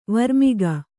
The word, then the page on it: ವರ್ಮಿಗ: varmiga; kannada